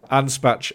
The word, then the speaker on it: Anspach: How do you say it again? Ab snack and Hobday. – yeah, why not? Yeah, sure. – Anspach and Hobday.